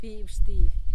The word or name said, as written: bibyxtily